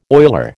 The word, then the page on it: oiler: American English